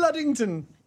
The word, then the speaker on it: Luddington: Luddington. Am I saying that properly? – Yeah, you're saying it right.